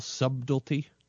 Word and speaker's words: subtlety: My favorite movie titles are the ones that tell you everything you need to know, that lack all subtlety, which is how to pronounce the word properly. Subtlety. There's a B in there, I think.